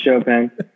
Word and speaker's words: Chopin: It's Chopin. – Pronounced Chopin.